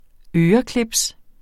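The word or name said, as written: ØRECLIPS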